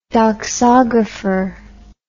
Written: doxographer

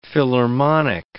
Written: philharmonic

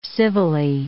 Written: civilly

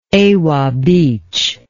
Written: Ewa Beach

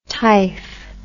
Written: typh

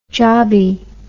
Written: jobbie

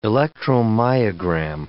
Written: electromyogram

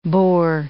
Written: boar

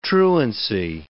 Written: truancy

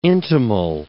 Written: intimal

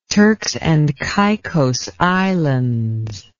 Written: Turks and Caicos Islands

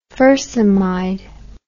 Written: fursemide

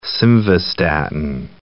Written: simvastatin

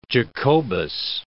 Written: Jacobus

